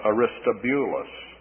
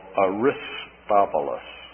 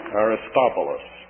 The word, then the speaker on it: Aristobulus: Sometimes this is given the pronunciation of Aristobulus. In fact, the version I'm reading has it that way.